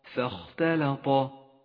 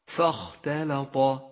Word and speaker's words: فاختلط: فاختلط، ولاحظ أن حرف الفاء حرف مرقق، وهمزة الوسط الداخلية التي بعده لونت بالرمادي لأنها لا تلفظ، والخاء حرف مفخم، والتاء واللام حرفان مرققان، والطاء حرف مفخم. فانتقل ببراعة بين الترقيق والتفخيم، وأعطي كل ذي حق حقه.